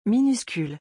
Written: minuscule

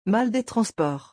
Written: mal des transports

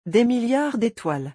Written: des milliards d'etoiles

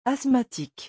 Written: asthmatique

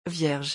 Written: vierge

